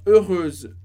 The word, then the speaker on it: heureuse: A diferença está no S do heureuse, o som de Z no final. Heureuse, feminino. Heureux, masculino. Felizes.